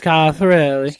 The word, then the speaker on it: Schiaparelli: Or Schiaparelli. Good. I never know how to pronounce that. – It's Schiaparelli.